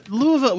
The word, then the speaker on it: Louisville: Louisville, we've had like maybe a half dozen friends of ours who live in Louisville. I'm told it's only two syllables, by the way. It's Louisville.